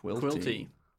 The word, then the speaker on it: Quilty: Quilty. – Just a man. – Rhymes with guilty.